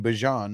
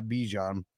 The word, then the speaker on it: Bijan: That's how he said he pronounces his name, Bijan, not Bijan.